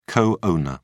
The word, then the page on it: co-owner: British English